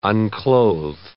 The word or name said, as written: unclothe